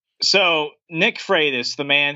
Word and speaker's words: Freitas: It's pronounced Freitas, like Frito, like Freitas. – Like yeah. – Freight train. – Like freight train. And then us.